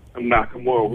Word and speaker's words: Nakamura: Oh, Nakamura? – Nakamura.